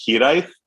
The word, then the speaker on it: Hiraeth: It's a Welsh word. It's spelled Hiraeth. So it is definitely going to be a difficult one for people to pronounce. It's actually pronounced Hiraeth, but